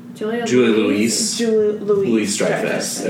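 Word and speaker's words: Julia Louis-Dreyfus: Let's say her name, because I always miss Julia Ray Dreyfus, is that it? – Julia Louise Dreyfus.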